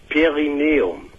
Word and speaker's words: Perineum: Also Perineum.